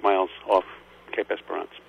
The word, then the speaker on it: Esperance: Am I pronouncing it correctly? Cape Esperance? – Esperance did very well.